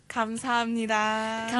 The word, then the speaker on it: 감사합니다: 감사합니다. – 감사합니다. – 감사합니다.